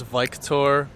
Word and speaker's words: Viktor: My name is Viktor Paris, complete stranger. – Viktor, can you spell that? – V-I-K-T-O-R. – So it's like Victor, but like in a different way.